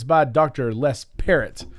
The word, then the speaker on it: Parrott: We Perot. – We thought it was Perot. – Perot. – But we came up with Parrott.